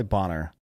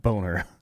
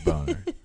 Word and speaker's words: Bonar: That is B-O-N-A-R. – It's probably Bonner, not Boner.